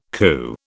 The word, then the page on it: CU: British English